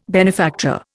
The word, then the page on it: benefactor: Indian